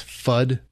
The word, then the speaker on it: PhD: Phonetically, it's FUD. That's what it sounds like when it hits something. So it is a CNC machined projectile that has pedals cut back into it.